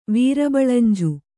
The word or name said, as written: ವೀರಬಳಂಜು